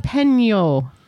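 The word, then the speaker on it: jalapeño: It's a jalapeno. – Jalapeno. Yes, jalapeno. – There's a little tilde above the N.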